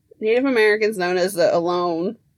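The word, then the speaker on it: Ohlone: It's O-H-L-O-N-E, so like alone. Not alone.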